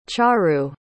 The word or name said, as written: charu